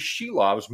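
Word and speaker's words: Šilovs: Uh, we've been saying it wrong, apparently, uh, for his time in the organization. It is Shilovs moving forward here now,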